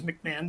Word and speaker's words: McMahon: The pronunciation is McMahon. The H is silent.